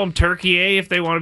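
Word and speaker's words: Türkiye: Three syllables, Turkey-ye.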